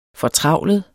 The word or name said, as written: fortravlet